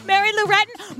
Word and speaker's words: Mary Lou Retton: Mary Lou Reddard. I've really butchered that name. – Mary Lou Retton, Marcia Clark.